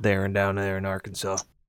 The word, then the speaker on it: Arkansas: And Huvian, it's not pronounced Arkansas. It's Arkansas.